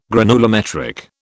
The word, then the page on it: granulometric: British English